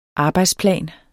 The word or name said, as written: arbejdsplan